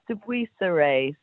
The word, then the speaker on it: de Buisseret: It's de Buisseret. So de Buisseret. You just don't pronounce the T at the end.